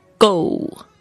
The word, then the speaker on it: goal: goal, 这个要特别注意一下它的这个发音呢， OA 这个发音是发 O 这个双元音，所以呢它不是只有一个声音，不是 O 的声音，而是 O, goal。